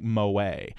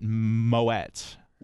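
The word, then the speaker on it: Moët: Only, but the key was that T. You're absolutely, boy, we didn't catch him, Ross. – It's really interesting because both Kathy and I made the mistake and we pronounced it moe, which is absolutely wrong.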